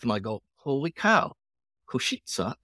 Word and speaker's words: Košice: Košice, is that how you say it? Košice.